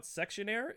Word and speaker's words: Sexionaire: If you guys, uh, if you guys uh, enjoyed that, um, make sure you guys check out Sexionaire. It is uh, spelled like questionnaire but with sex instead at the beginning of it.